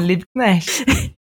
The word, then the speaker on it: Liebknecht: Isso mesmo, Liebknecht.